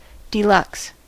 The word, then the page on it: deluxe: [də.ˈlʌks]; /də.ˈlʊks/